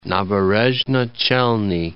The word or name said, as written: Naberezhnye Chelny